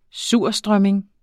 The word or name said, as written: surstrømming